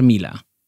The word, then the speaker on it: Milla: Luego, Roger Mila. Roger Mila, que se escribe Milla también, de Camerún.